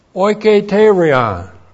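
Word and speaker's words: Oiketerion (οἰκητήριον): O-I-K, long E-T, long E-R-I-O-N. Oiketerion.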